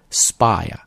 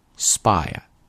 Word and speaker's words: spire: OK, next word, spire. Spire. 注意这个单词的发音啊， P 是不送气的啊， spire.